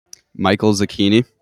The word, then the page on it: Michael Zecchini: male